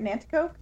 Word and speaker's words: Nanticoke: In Nanticoke? Is that how you say it? Nanticoke? Mm-hmm. Pennsylvania.